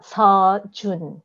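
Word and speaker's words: So. Jun (서준): It's not Seo. It's more like S-U-H. So.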